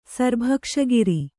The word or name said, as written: ಸರ್‍ಭಕ್ಷಗಿರಿ